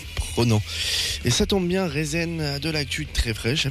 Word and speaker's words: Rezn: Rezn, R-E-Z-N, ça se prononce comme ça, et c'est beaucoup de choses à la fois.